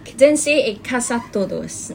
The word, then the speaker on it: Quédense en casa todos: Quédense en casa todos. Did I say right? – Probably need a little bit of improvement. – Close enough. Okay, now I'm just kidding. Okay, you pronounce it right.